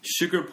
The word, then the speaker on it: sugar: 그 설탕, sugar이라고 하면은 SH처럼 해요. – 네. – 그죠? 근데 보통 S만 나오면은 그냥 S로 해요. 그러니까 네, 그래서 sugar 보면은 she처럼 발음을 해요.